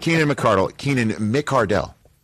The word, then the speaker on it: McCardell: Close to the fact that Keenan McCardle came out and said, no. Excuse me. I am Keenan McCardell. McCardell.